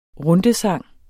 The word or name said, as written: rundesang